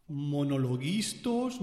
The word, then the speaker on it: monologuistas: Monologuistas, monolistos, monolistas, monolingüistas, lingüística, pronunciación es lo que le falta a este tío. Monologuistas. Tan difícil no es.